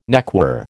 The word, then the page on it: neckwear: American English